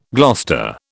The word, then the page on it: Gloucester: British English